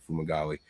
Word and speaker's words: Fumagalli: Someone typed it out there phonetically, Fumagalli.